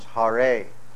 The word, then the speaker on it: Hare: And the word Hare, now in the root form, it's Hara, H-A-R with a long A, Hara. And then in the vocative case, it becomes Hare.